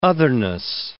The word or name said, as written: otherness